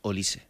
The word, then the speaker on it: Olise: ¿Tengo claro cómo se pronuncia? – Ahora ya lo sabemos, Olise. Andamos con dudas. Un día Olise, otro día Olise, y ahora es Olise. – Es Olise.